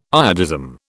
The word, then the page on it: iodism: British English